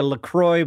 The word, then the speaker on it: LaCroix: It shouldn't even be pronounced LaCroix, because it's a French thing, but we've taken French names and made them more American sounding.